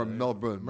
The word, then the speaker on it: Melbourne: Melbourne, as we just said. – Or Melbourne. – Melbourne. – Melbourne. – Okay. Gotta get that pronunciation down.